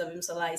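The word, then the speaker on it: Abimbola: Like, at work, they call me Abim Sola. I always introduce myself. Hi, my name is Abim Sola. It's pronounced exactly as it's spelt, I promise. If you think too much, you will mess it up.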